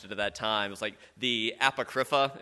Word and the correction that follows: Apocrypha: It's actually the Apocrypha, right? In the South, we like to put the emphasis on the first syllable, right?